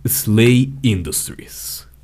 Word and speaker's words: SLA Industries: Se llama Slay Industries pero se escribe SLA Industries.